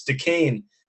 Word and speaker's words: Duquesne: But I'm going to go with the team that we finally figured out how to pronounce, and it's Duquesne.